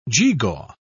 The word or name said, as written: geegaw